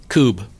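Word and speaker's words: Kubb: is it pronounced cube? Cub? – Cube. – I call it Cub. Oh. – I've heard Coob, so.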